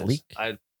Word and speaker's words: Leitch: Paul Taylor is played by Donovan Leitch Jr. or Lechich. I said that wrong. I apologize. – I think it's Leek. – Is it Leek? – I think so.